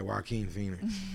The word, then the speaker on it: Joaquin Phoenix: I don't know how to say the first name. Is it Joaquin Phoenix? – Yeah, Joaquin Phoenix.